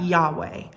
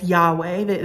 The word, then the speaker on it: Yahweh: Now, we don't know how it's pronounced for sure, um, but most often we pronounce that Yahweh.